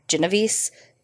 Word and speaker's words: Genovese: And I've heard her name pronounced a few different ways, but it seems to be the consensus is Genovese, but we're going to call her Kitty because that was what she went by.